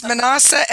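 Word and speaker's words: Manassa: What boxer was nicknamed the, oh, my gosh, the, I want to say the, Manasa Mahler? – Where did she get these questions from? – The internet. And I could spell this. It's Manasa, M-A-N-A-S-S-A. I mean, you could say Mahler, M-A-U-L-E-R.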